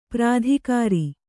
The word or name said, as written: ಪ್ರತಿಕಾರ